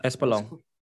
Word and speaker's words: Espolón: I always say it wrong. Espinal? No. – Espolón.